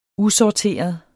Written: usorteret

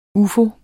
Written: UFOER

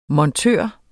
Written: montør

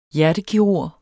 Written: hjertekirurg

